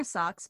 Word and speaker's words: Socks: My name is Socks Whitmore. Socks spelled like the things on your feet.